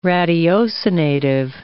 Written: ratiocinative

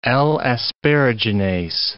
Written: L-asparaginase

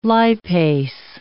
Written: lipase

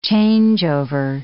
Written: changeover

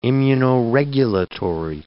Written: immunoregulatory

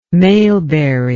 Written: maleberry

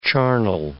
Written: charnel

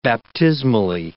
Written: baptismally

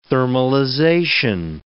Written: thermalization

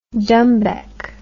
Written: dumbek

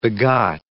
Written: begot

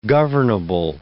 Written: governable

